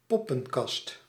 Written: bad

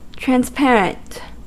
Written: transparent

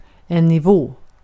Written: nivå

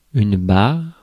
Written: stripe

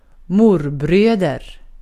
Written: morbröder

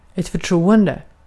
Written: förtroende

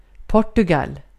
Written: Portugal

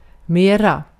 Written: more

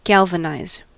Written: galvanise

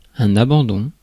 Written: abandon

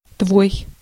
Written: your

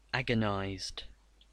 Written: agonized

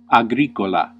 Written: farmer

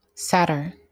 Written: satyr

